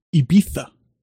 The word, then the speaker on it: Ibiza: It's pronounced Ibiza. – Yeah, they're talking about a place because they said it like an asshole. Ibiza. They saw it like an elitist cuck. Ibiza. I hate when people say that. – That is apparently how things are pronounced in España.